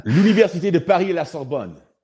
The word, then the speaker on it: L'Université de Paris à la Sorbonne: L'Université de Paris à la Sorbonne.